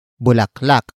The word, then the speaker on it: bulaklak: And that is bulaklak.